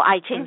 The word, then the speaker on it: I Ching: In Chinese, it's called the I Ching, or I Ching, some people pronounce it.